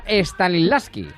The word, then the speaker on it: Stanislavski: del método Stanislavski Ya lo has dicho bien.